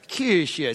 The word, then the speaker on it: Kirche: Or if it's, we actually called up a German guy and said, how do you pronounce Kirche? And he's like, Kirche. It sounded like he was sick on the phone.